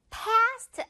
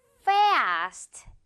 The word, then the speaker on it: fast: 이거 fast 아니고 이렇게 입 양 입술이 맞닿으면 안 되죠. 이 발음으로 시작하면서 F 발음 어떻게 발음한다? fast 이렇게 발음이 됩니다. 이렇게 바람 내면서 fast. 그리고 그냥 fast 이렇게 짧지 않아요. 지금 이 A 발음은 긴 고무줄 늘린 그 발음이라서 fast 이렇게 크고 길게 발음해 주시면 됩니다.